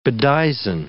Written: bedizen

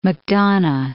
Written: Macdonough

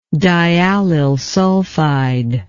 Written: diallyl sulfide

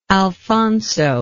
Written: Alphonso

